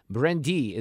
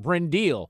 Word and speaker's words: Brindille: The restaurant's name is, it's spelled B-R-I-N-D-I-L-L-E. Brendy is the French name, but I'm going to call it Brendil just because we're from Missouri.